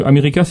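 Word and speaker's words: AmeriKKKa: Et je précise, si vous cherchez, que America s'écrit A-M-E-R-I, 3K, comme le Ku Klux Klan, A.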